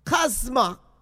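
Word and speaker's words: kosma (κόσμος): Now, the great Greek word that's translated in world, it is pronounced kosma, and that's where we get our word cosmos.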